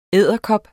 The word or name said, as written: edderkop